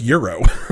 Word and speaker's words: gyro: No, it's Euro. – Euro? – Yeah. I remember the first time I came out here. – The G is silent? – Yeah.